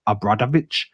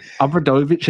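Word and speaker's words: Obradović: Zico Obradovic, or Obradovic, is... Aaron Brockovich? – No, not Aaron Brockovich. But Obradovic, as I pronounce it, is unquestionably one of the greatest coaches in European League history.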